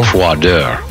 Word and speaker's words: froideur: Froideur. Is F-R-O-I-D-E-U-R. – Well, I got the last four letters right. – Yeah. But the F-R-O-Y is what throws people off. That's Froy, but it's pronounced Froy.